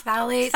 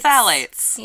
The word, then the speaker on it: phthalates: The phthalate, there's a T in there as well. – Yeah. Phthalates? – Phthalates. – Phthalates. – Yes.